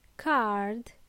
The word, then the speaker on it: card: First word, card. Card. It's not car, it's card. Please pronounce the last letter in this case. Card. Last letter D.